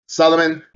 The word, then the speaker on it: Salman: I think Salman. – Yeah, you said it right.